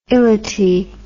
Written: ility

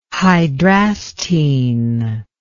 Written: hydrastine

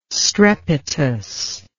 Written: strepitous

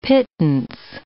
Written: pittance

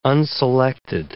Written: unselected

